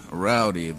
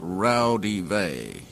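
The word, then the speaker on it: Raudive: And the most complete source book is a book called Breakthrough by Constantine Rowdy. That's actually pronounced Rowdive. It's Latin.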